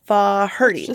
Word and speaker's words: Faherty: 76-year-old Michael Farady. I don't think that's how you say it. Yeah, okay. It's F-A-H-E-R-T-Y. Faherty. Faherty.